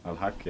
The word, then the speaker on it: Al-Haq: Am I pronouncing it right, Al-Haq? – Al-Haq, yes.